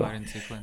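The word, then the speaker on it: vareniklin: ispravi me za izgovor variniklina, variniciklina kako, kako se veše zove? – Varinciklin.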